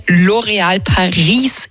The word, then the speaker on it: L'Oréal Paris: Die Kerstin hat bei L'Oréal Paris in Wien gearbeitet und uns gesagt, bei uns spricht man das so aus mit S.